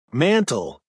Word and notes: mantel: American English

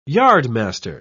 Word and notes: yardmaster: British English

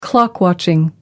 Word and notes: clock watching: American English

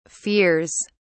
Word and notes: fears: British English

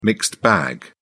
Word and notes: mixed bag: British English

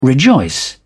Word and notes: rejoice: British English

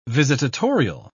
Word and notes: visitatorial: British English

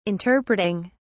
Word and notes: interpreting: British English

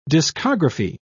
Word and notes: discography: British English

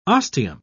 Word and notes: ostium: British English

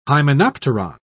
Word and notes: hymenopteron: British English

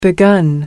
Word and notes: begun: American English

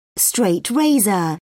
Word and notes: straight razor: British English

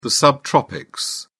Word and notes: subtropics: British English

